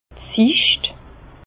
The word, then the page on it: d' Sicht: Bas-Rhin; Herrlisheim